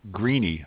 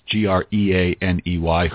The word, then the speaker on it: Greaney: Greeney, I think his last name is pronounced Greaney, who was the co-writer, if that's even the right word, with the last three Tom Clancy novels.